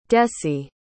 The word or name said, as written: dessie